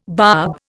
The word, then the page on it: barb: Indian